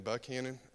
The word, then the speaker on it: Buchanan: For some reason, they pronounce Buchanan Buckhannon. I don't know why, but it sometimes annoys me.